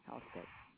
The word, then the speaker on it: Halstead: Is that J-E-A-N? – Right. And the last name is spelled Halstead. H is in hat. A-L-S is in Sam. T is in Tom. E-D is in David. Halstead.